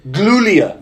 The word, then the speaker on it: Giulia: Julia would be best talent from Stardom to take the TBS title. – Giulia. Yeah. – G-I-U-L-I-A. Giulia.